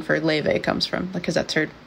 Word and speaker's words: Laufey: It's spelled Laufey, but it's pronounced Leve.